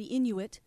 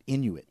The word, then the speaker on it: Inuit: Yes, they've done some studies recently that show in the frozen Arctic, the native peoples who live in this region, the Inuit. – That's how you pronounce it, Inuit. – Inuit. – Not Inui or...